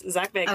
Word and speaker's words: Zach Bagans: Yes, Zach Bagans.